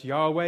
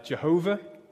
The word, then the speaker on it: Yahweh: In Hebrew, it's pronounced Yahweh. It used to be thought to be pronounced Jehovah, but it means in Hebrew, I Am.